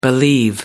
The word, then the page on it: believe: American English